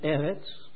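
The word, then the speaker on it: Eretz (ארץ): Ich habe das Wort Erde, hebräisch, gelesen, ausgesprochen, Eretz, und Himmel, Shamayim.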